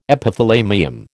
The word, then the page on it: Epithalamium: American English